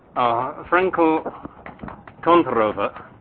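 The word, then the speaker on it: Frenkel-Kontorova: Okay, so Frankl Kontorova, as Pavel just told me I should pronounce it, model.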